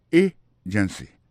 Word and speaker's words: agency: Pour mot agency, hein, accent tombé sous premier syllabe là. Agency. Deux autres syllabes qui sont derrière, ils sont faibles.